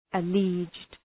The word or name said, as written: alleged